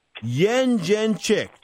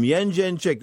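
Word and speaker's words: Jędrzejczyk: This is Yen Jen Chick. That's the way you say her name, Yen Jen Chick. According to Wikipedia, that's the way you say her name. Scott Cuddy's giving me this funny look in the other room because I think he says it differently.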